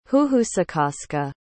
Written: huhusecaska

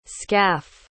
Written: scaff

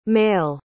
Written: maille

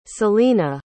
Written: selina

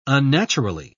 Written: unnaturally

